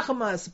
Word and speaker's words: Hermès: 而且如果大家喜欢 fashion 啊，那法国的更是好了，大家喜欢时尚喜欢 fashion，那法国简直啊天堂啊，是法国最著名的这个几大品牌啊，不要读错了 Hermès 爱马仕啊 Hermès 不要读错啊，Louis Vuitton，Louis Vuitton，